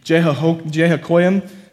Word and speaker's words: Jehoiakim: And the Lord gave, again, God is involved here, which is fascinating, Jehoiakim, it's definitely how you say it, different than the first time, king of Judah, into his hand with some of the vessels of the house of God.